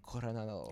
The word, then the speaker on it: Coronado: Or how is it officially pronounced? – Coronado. – Coronado.